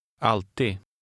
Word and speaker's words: alltid: In number 5, the D at the end is silent. – Läs nummer 5. – Alltid.